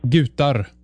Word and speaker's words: Gutar: Gutar is the word. – Oh, yes, not the G, not the hard G. It would be the U. – Yeah. – Yes, like Göteborg.